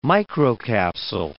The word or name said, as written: microcapsule